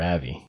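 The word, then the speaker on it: Avy: Yeah, it's spelled A-V-Y. I think they called her Avi. – Yeah, they definitely did.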